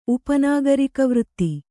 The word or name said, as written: ಉಪನಾಗರಿಕವೃತ್ತಿ